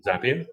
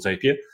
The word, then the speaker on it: Zapier: You mentioned Zapier, which I also call Zapier, although probably your pronunciation makes more sense. Zapier.